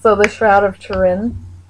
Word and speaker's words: Turin: It's a hard R. – Yeah. – Turin. – Yeah. It's a guttural R. – Turin. – That's how it's technically pronounced, but.